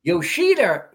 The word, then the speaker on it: Yoshida: Now, of the three players I looked at, Yoshida, am I saying that right? – Yeah. – Yoshida is more in style than the other two, but he has more swing problems, in my opinion.